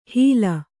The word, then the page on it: ಹೀಲ: Kannada